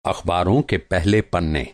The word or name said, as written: पन्ना